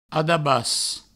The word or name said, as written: adabas